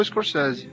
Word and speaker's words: Scorsese: O Scorsese tem um problema, porque a gente sempre falou Scorsese. – Mas em inglês a pronúncia do nome dele mesmo correta é Scorsese.